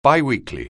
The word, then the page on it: biweekly: anglais